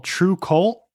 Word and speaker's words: Trve Kvlt: And I'm pretty sure it's called True Cult, even though it's spelled like T-R-V-E-K-V-L-T.